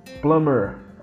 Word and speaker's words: plumber: Plumber, essa palavra se pronuncia plumber, plumber, tem um B aí que é P o P-L-U-M-B-E-R, se lê A leitura seria plumber, mas a pronúncia é plumber, plumber.